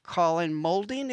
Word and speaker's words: Moulding: and that's Andy Partridge and Colin Moulding.